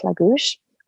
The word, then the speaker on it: Lagos: And we stayed there in a town called Lagouche, which is written Lagos, but pronounced Lagouche.